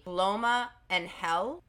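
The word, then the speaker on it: Loma de Ángel: One of their larger brothels was the Loma en Hell, but it's spelt Loma de Angel, but they pronounce it Loma en Hell.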